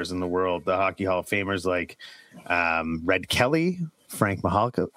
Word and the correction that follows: Mahovlich: how do you say it? – Frank Mahovlich.